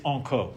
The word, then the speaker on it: incog: It's enco. – Enco is how you say it in French. Right. It's like okay in English. It's a French abbreviation, which is pronounced enco, and it's an abbreviation for incognito.